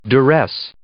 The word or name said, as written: duress